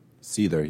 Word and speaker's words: Seether: Seether? – Seether, yeah.